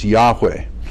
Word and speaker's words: Yahweh: but now we're pretty confident that that the way to pronounce his name is Yahweh, the best we can understand it.